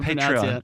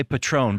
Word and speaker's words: Patreon: Follow us over on to Patreon. It's Patreon, right? I always say it wrong. – I think that's the Southern Patreon. Patreon. – Yeah. I wanted to say Patron, but I know that's not right.